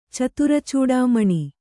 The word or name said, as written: ಚತುರಚೂಡಾಮಣಿ